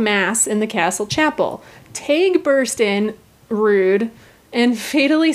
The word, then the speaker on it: Teighe: I had to Google how to pronounce it because I thought it was Teague. But no, it's spelled T-E-I-G-H-E, which is apparently pronounced Taig.